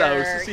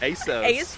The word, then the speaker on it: ASOS: ASOS. Yeah, you mispronounced it. ASOS.